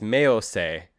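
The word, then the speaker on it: Meosei (冥王星): They use specifically the Japanese name for the planet, which is Meosei.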